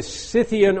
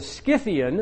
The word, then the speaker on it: Scythian: Or the Scythian or the Scythian. Again, the SC could be an SK, and we'll talk about that in due course.